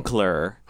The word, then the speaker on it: Kunkler: Kunkler. Yeah. – K-U-N-K-L-E-R.